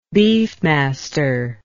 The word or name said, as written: beefmaster